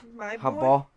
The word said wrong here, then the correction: Harpal: Oh, my boy, Harpal, my little brother. – They say his name right.